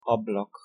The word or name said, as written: ablak